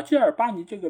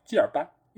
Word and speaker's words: Kilbane: 啊，基尔巴尼这个名字，其实你照理来说应该是叫基尔班，因为它最后是 b a n e 结尾，它不应该有 a n e 的一个一个发音在这边啊，所以这个其实也是另外一个会比较容易读错的一个点。